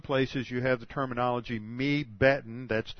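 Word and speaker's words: mibbeten: M-I-N plus beten, B-E-T-E-N, and when they're joined together, you lose the N, and it's just pronounced mebeten.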